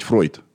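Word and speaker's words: Freud: Собственно, Фрейд был довольно образованный в еврейском плане человек. Если я не ошибаюсь, правильно говорить Фройд.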